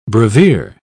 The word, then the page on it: brevier: British English